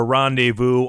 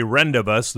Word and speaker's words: rendezvous: Country Legends Jukebox back into the 70s with Johnny Duncan and Janie Fricky, thinking of a rendezvous, or a rendibus, the way it's spelled.